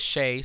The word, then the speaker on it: Shae: Her name is Shay, so her name mm-hmm. is spelled S-H-A-E.